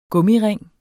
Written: gummiring

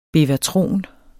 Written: bevatron

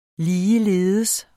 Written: ligeledes

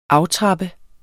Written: AFTRAPPER

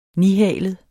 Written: nihalet

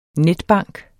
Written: netbank

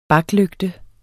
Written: baklygte

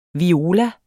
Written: viola

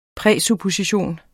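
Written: PRÆSUPPOSITION

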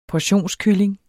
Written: portionskylling

